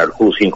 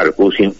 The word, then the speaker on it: Arcusín: Es decir, mi abuelo era Arcusín como yo, S-I-N, y tenía un hermano de padre y madre, que estaba anotado acá como Arcusín, S-H. – Bueno. – ¿Sí? O sea que habían entrado en distinto momento y a uno lo anotaron porque él pronunció Arcusín con la pronunciación rusa y le pusieron S-H.